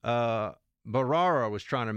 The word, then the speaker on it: Bharara: I thought it was Brar. I always get this. Is this guy's name? Am I getting his name wrong here? Bharara. It's Bharara.